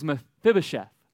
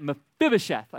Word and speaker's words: Mephibosheth: His name is Mephibosheth. Mephibosheth. Mephibosheth. All right, you got that? Mephibosheth. I said it right at least five times.